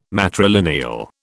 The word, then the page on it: matrilineal: British English